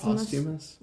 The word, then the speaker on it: posthumous: Taylor Hawkins' first posthumous... I cannot pronounce words. – I think it's just posthumous. – Yeah, posthumous.